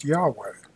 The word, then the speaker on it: Yahweh: The Hebrew word, translated as Lord in the Bible, is YHWH, which in English is pronounced Yahweh.